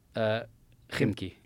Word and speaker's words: Khimki: How do you say that word? Khimki? – Khimki. Yeah.